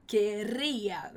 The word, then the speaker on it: querría: Então, yo querría, muito cuidado que a gente tem esse RR, mas a gente não pronuncia igual no português, hein, com querría, ok? A gente vai falar querría, vai só vibrar a sua língua mesmo.